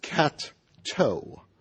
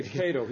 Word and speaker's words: Cato: it was pronounced cat-toe. – And other kids tried to say Cato. He should correct them by saying it's like a cat's toe.